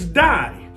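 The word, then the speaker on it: die (δεῖ): But now the word need in the Greek, the word need in the Greek is die. Like the English word die, D-I-E, it's die. But get this, it's not spelled D-I-E. It's spelled D-E-I.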